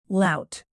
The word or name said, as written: lout